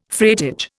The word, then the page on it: freightage: Indian